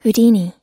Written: houdini

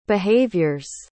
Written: behaviors

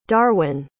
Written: darwin

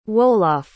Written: Wolof

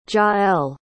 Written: jaelle

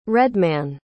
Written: redman

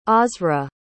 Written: osrid

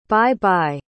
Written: bye-bye

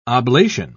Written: oblation

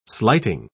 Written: slighting

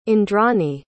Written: indrani

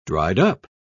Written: dried-up

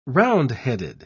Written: roundheaded

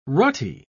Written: rutty

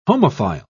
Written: homophile

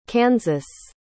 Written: Kansas

